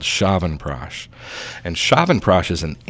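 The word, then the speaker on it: Chyawanprash: What is Shavan Prash? I'm hoping I'm saying that right. – You are. Very good. Good job.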